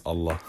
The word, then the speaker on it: Allah: Allah.